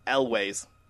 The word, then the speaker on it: Elwes: I believe it's pronounced Elwes.